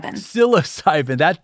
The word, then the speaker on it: psilocybin: one creates basically a cottage industry of new therapies out of, you corrected me during our pregame, psilocybin. Did I pronounce that correctly? – Psilocybin.